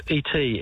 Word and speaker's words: et: Well, fortunately, I studied both those languages in high school, so I better get, even though it's pronounced differently, slightly, it's E-T, et.